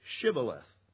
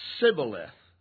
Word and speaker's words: Shibboleth: Then they would say to him, "Then say Shibboleth." And they would say Sibboleth, for he could not pronounce it right.